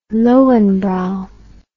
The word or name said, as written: Lowenbrau